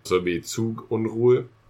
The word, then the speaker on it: Zugunruhe: And the U's are much more rounded, and the E sound is a much lower E sound than the English E. – Say it again. – Zugunruhe.